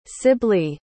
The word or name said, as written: sibley